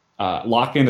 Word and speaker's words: LOCKN: Lock-In is spelled LOCKN.